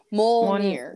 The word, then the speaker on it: Mjolnir: Is it Monier, something like Mjolnir.